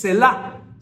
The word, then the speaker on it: Selah: Aquí hay una palabra en los Salmos que no mucho le ponemos atención, que es Selah. – Se escribe S-E-L-A-H.